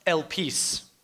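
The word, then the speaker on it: elpis (ἐλπίς): The Greek word for hope is elpis, which is actually pronounced elpis.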